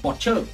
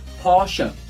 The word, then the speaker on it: Porsche: Hãng thứ hai người Việt hay đọc là Porsche cũng không hẳn là quá sai mọi người chỉ cần đổi chữ chờ thành chữ sờ. Porsche.